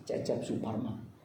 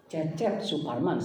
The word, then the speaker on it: Cecep Suparman: My name is Cecep Suparman. – Mereka mau konfirmasi, what? Cecep Suparman saya bilang.